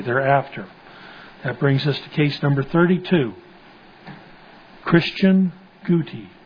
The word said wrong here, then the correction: Christiane: How do you pronounce it? – Christiane.